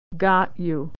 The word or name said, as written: got you